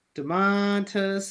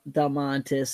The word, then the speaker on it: Domantas: Say his name one more time. – Dontas Sabonis. – Dontas? – Damantas. Damantas Sabonis. – Domantas. – Damantas Sabonis. – Yeah, someday you'll get it.